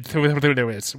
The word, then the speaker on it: Thewlis: Yeah, it's quite a simple last name. It's two syllables. – Thewlis. I'm so, the Britishness of it all. – It's like the Thames.